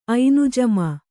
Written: ಐನುಜಮೆ

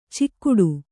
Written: ಚಿಕ್ಕುಟು

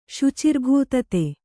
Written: ಶುಚಿರ್ಭೂತತೆ

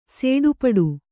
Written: ಸಡಪುಡ

